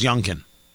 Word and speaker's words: Youngkin: Youngkin. It's pronounced Youngkin.